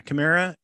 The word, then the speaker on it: Chimera: And Ezra has made friends with the Purrgil, and they actually glom on to the Chimera, I think is how you say it. Yeah, Chimera, and that's Thrawn's command ship.